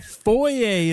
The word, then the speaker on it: foyer: This French word for an indoor entryway is often mispronounced by Americans. What's the word? – Foyer? – Foyer is correct, and Americans often say it foyer.